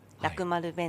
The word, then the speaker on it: 楽丸: ますか拓殖楽丸楽しい丸と書いて、楽丸弁当ってい